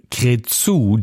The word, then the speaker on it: Cretu: Platz 29 für Sandra Heaven Can Wait, produziert damals von Michael Cretu oder Cretu, haben wir damals nicht gewusst, wie spricht sich dieser super duper Pop-Produzent eigentlich aus. Heute weiß ich es, Michael Cretu, denn er kommt aus Bukarest, aus Rumänien und hat seiner Sandra wirklich Pop-Songs geschneidert.